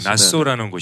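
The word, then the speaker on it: Nassau: 수도가 나쏘. – 나쏘. – 예, 나쏘라는 네.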